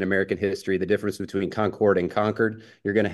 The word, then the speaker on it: Concord: Up north they say Concord, but it's Concord, North Carolina.